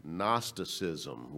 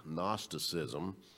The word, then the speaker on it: Gnosticism: And so false doctrines would rise up, and one of those false doctrines was called, let me pronounce it right, Gnosticism, with a G, Gnosticism.